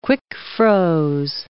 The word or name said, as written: quick-froze